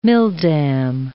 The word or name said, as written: milldam